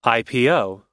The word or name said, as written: ipo